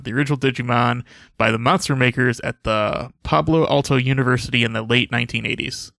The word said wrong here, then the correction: Palo Alto: Pablo Alto? – Palo Alto? I can't pronounce shit. – Wait, P-A-L-O? – Yeah. – Ah, okay, yes. – How do you say it properly? – Palo Alto.